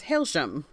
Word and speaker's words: Hailsham: The summary on Book Riot says Hailsham. – Oh, it's Hailsham. – It's Hailsham.